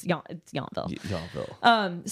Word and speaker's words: Yountville: I think technically it's pronounced Yountville, like in Fury, but it's Yountville.